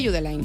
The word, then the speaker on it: Judeline: No es Judeline, Judeline. Si es de Barbate, Judeline.